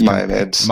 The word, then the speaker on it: Mia Maids: Key changes include what's reported and the opportunity to invite Laurels and Mia maids to serve as Maya maids. – Maya maids, okay.